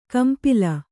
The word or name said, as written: ಕಂಪಿಲ್ಲ